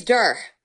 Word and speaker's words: Duhr: My last name's Duhr. – Spell that for me. – Yeah, D like dog, U-H-R. – Duhr, D-U-R-R.